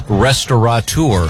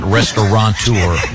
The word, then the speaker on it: restaurateur: I pronounce it restaurant in my head, and it's that gives you the T-A-U there before you go. – Oh, okay. – I know that the most mispronounced is restaurateur. – Mm-hmm. – There's no N. It's not restaurateur.